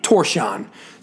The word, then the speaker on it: Tórshavn: I called it Tórshavn because it looks like, it's spelled like T-O-R-S-H-A-V-N. So Tórshavn's a good way to go phonetically. I have learned through the wonders of the internet it is actually Tórshavn.